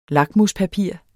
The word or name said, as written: lakmuspapir